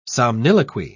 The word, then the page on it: somniloquy: British English